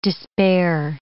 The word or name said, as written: despair